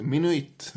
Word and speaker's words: Minuit: Ja, jag försöker, jag letar efter min inre flamländare, men jag hittar honom inte. En holländsk affärsman i alla fall. – Ja. Och något åt det hållet uttalas det. Minuit.